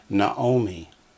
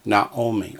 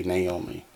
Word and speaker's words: Naomi: Her name is Naomi. Again, Naomi. Or in English, we say Naomi.